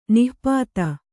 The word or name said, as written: ನಾಪಿತ